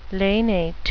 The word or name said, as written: lanate